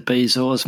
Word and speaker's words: bezoar: Let's jump into bezoars, Mark. I've just looked it up, and, the most common pronunciation is bezoar.